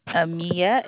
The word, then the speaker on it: Amia: My name is Amia. How may I assist you? – Yeah, what's your name? – Amia, A-M-I-A. – M-A-I. I'm going to spell it again, please. – Amia, A-M-I-A.